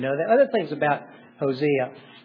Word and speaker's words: Hosea: And you say, well, the first thing I learned about Hosea is Rocky sure pronounces it funny. It's Hosea. Either pronunciation's fine. I do Hosea.